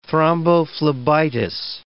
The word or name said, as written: thrombophlebitis